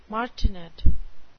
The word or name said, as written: martinet